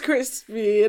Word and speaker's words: Chrispy: And my name is Crispy, and that's spelled C-H-R-I-S-P-Y.